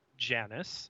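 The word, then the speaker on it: Janus: Giannis, properly pronounced Janice, is indeed the two-faced god of Roman mythology.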